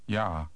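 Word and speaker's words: já: Word number 17 begins with the same sound as the English word you. Read number 17. – Ja.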